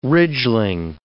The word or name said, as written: ridgling